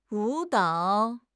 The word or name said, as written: 舞蹈